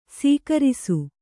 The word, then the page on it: ಸೀಗುರಿಸು: sīgurisu